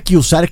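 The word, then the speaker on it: Q-Zar: El Kusar se pronuncia Kusar, que es en inglés.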